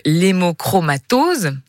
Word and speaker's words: hémochromatose: Et alors cette soirée dont on parlait se déroule durant la semaine mondiale de l'hémochromatose, je l'ai bien prononcé. – C'est bien ça.